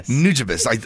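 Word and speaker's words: Nujabes: So, Nuribis, right? Nuribis? – What? – Nuribis? How do you say your favorite beat maker? See, because I messed it up, the name.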